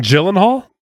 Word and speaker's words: Gyllenhaal: I was saying Gyllenhaal? – You were saying hard G, Gyllenhaal. – I was saying Gyllenhaal, and it's Gyllenhaal.